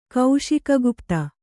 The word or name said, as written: ಕೌಶಿಕಗುಪ್ತ